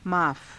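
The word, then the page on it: माफ़: maafa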